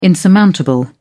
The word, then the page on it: insurmountable: British English